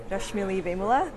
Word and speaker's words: Rashmili Vemula: But hey, everyone, my name is Rashmili Vemula. The first time I actually say it like in a traditional Telugu way, my name.